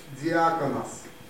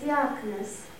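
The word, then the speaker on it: диаконос (διάκονος): оно на греческом звучит как диаконос. – And where Jesus says that no, you have to become a servant. In Greek, the word servant is pronounced as diakonos.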